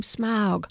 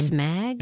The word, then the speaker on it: Smaug: My eldest child walked outside into the cold and went, "Look, I'm Smaug."